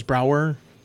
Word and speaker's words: Browar: you you're talking about um, um, as it, it, uh, uh, the pronunciation is Browar. Browar Fortuna.